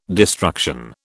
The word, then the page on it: destruction: British English